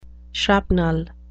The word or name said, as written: shrapnel